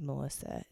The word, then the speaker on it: Melissa: So I gave my name, and my name is Melissa.